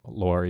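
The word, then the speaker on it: Laure: Laura, yeah, my French pronunciation. – With an E rather than an A at the end, yeah. – The French.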